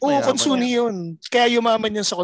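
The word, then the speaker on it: Consunji: Konsunhi. – Mayaman yan, mayaman. Kasi may silent letter.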